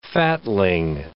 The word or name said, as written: fatling